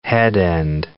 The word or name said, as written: headend